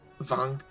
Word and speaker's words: Rotwang: Also, I don't know if you'll care or not, and considering you've already said the name a few times, but I think it's Rotwang. – Vong. Oh, it's a like Wagner. Shit. – Yeah, because okay. Because with German, the W is pronounced like a V.